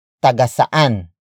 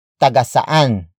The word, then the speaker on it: Tagasaan: One of which is tagasaan. Tagasaan. Tagasaan. Tagasaan is spelled as T-A-G-A-S-A-A-N.